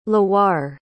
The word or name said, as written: loire